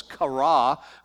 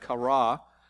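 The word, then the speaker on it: kara (קרא): Hebrew word call is kara. We would spell it Q-A-R-A, something like that, kara.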